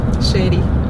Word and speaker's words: Shedy: Shedy. – Oh, yeah, Shedy. – Yeah. – How do you write that? – S-H-E-D-Y.